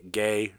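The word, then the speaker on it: Gey: It's G-E-Y. Gay. – It's definitely gay. – Yeah, it's definitely gay. So. – And that's also how his name is pronounced.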